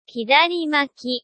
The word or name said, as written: hidarimaki